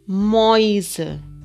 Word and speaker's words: Mäuse: S, esas vocales se pronuncian juntas hoy, como en hoise, casas, y Moise, ratones.